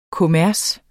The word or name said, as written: kommers